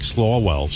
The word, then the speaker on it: Swalwell: First of all, this guy, Representative Eric Swalwell, seems to be on every MSNBC show.